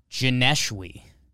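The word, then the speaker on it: Joneshwi: How do you say it? – Janeshwi. – Oh, I like that. – It's not John's way? – It's not John's way.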